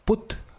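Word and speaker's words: Put (путь): Es una V, o el símbolo este que tienen ellos de suavizar un fonema, que es como escrito una V en minúscula, que se pronuncia V, y put, que es vamos, es put, así, es muy sencillo, es muy curioso.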